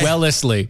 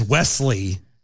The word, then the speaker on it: Wellesley: Because my brain was reading it as Wesley. – Yeah. – And it is as Wellesley.